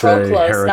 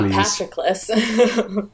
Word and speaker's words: Patroclus: It's Patroclus, not Patroclus.